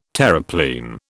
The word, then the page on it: Terreplein: British English